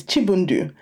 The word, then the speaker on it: Chibundu: And I said, "My name is Chibundu."